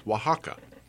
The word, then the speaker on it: Oaxaca: Each region, the state in Mexico that I'm really fascinated with that I want to travel to is Oaxaca. You would not pronounce it that way if you saw it spelled because the first letter is an O.